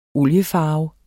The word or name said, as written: oliefarve